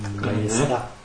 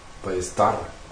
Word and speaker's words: Ballesta: Wie auch immer der spanischen Aussprache mächtig ist. – Genau. Marques de Ballesta. Ballesta. – Ballesta. – Siehst du ja.